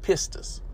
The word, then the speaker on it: pistis: Now, you know, in this verse, the word faith is the Greek word pronounced pistis.